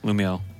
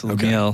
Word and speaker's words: Lumiel: Lumiel. That's how you say it, right? There's no, like, weird... – Lumiel. That is correct. Lumiel.